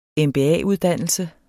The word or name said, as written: MBA-uddannelse